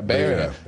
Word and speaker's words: Beira: The coastal town of Burai, is that how you pronounce it in Mozambique? – Beira.